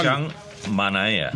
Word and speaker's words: Sean Manaea: Sean Manaea.